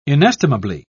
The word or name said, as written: inestimably